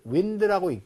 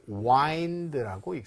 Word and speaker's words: wind: 그런데 구불구불하다라고 할 때는 이거를 윈드라고 읽지 않고 와인드라고 읽습니다.